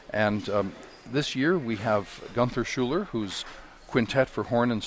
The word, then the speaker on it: Gunther Schuller: I love that he calls himself Gunther Schuller, which is the American pronunciation of a German name, but he's American.